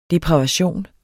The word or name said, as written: depravation